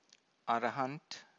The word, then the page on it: arahant: Pali